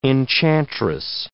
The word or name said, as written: enchantress